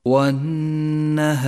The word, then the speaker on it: والنهار: انتبه إلى اللام الشمسية في كلمة والنهار والنهار، اللام الشمسية تكتب ولا تلفظ، ولذلك لونت بالرمادي إشارة إلى عدم نطقها، والواجب بعدها أن تقف بغنة زمنها حركتان على حرف النون، ولذلك لون باللون الأخضر، تقرأ الكلمة هكذا: والنهار والنهار.